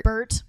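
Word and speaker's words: Berthe: Bert.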